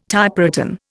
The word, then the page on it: Typewritten: Indian